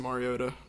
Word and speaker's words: Mariota: And I think this offense is really going to improve, really open it up for Marcus Mariota to run the ball and throw the ball. – And I'm proud of you for saying his name right, Marcus Mariota's name.